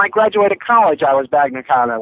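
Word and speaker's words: Bagna cauda: Bagna Cotta? Cotta. – Yep. – That's it. – I don't even know how to pronounce that, but yeah. – B-A-G-A. – Bagna Cotta. Is that it? – Yeah, C-A-U-D-A.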